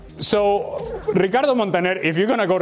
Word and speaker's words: Ricardo Montaner: Guaco or Ricardo Montaner? – Oh, okay.